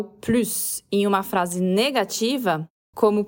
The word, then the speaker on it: plus: Como é uma negação, a gente não pronuncia o S de plus. Então, aqui, a pronúncia é plus, com o U, o biquinho do francês, e o S mudo.